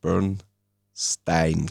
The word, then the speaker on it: Bernstein: Juni die Uraufführung der Oper A Quiet Place von Leonard Bernstein. Glaube ich, so spricht man aus. Also nicht Bernstein.